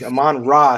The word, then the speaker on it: Amon-Ra: Did I say it right? Is that? – I think you might have forgotten a syllable in there somewhere. – Yeah, there. That's what I think. Amon-Ra St. Brown.